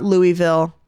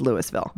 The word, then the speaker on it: Louisville: Otherwise, you're pretty quickly corrected, which is how do you pronounce Louisville? – Louisville, for sure. – This is not Louisville. That's not how we say it here. Yep. It is Louisville.